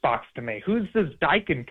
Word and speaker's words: Daikin: It is Daikin Park, but yeah, it just doesn't have the same ring to it.